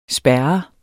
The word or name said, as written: spærret